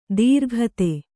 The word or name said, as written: ದೀರ್ಘತೆ